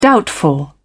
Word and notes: doubtful: British English

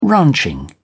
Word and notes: ranching: British English

